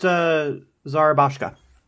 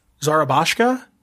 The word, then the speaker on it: Zarabashka: It's pronounced Zarabashka. – Zarabashka? Yep. Is that Russian or Polish? – It is Slavic in nature.